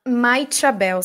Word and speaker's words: Maixabel: Maichabel, se escreve M-A-I-X-A-B-E-L.